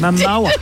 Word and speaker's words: Momoa: I was sure you said his name wrong, but you Momoa. Think of mowing your lawn.